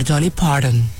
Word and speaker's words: Dolly Parton: ¿cómo se dice Dolly Parton? – No, señor, Dolly Parton, y usted sabe.